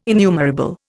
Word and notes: innumerable: Indian

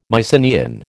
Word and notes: Mycenaean: American English

